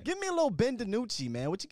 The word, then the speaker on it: DiNucci: But I called him Ben Houdini at first because I didn't know how to say his last name. So Ben DiNucci.